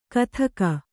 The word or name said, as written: ಘಾತುಕ